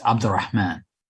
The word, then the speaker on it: Abdulrehman: Last name is pronounced Abdulrehman.